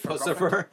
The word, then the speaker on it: Puscifer: Pussifer? – It's actually called Pussifer. Yeah. – Really? Wow. – Yeah, it's spelled, I think, P-U-S-C-I-F-E-R. – Mm. Like Lucifer? – But with puss.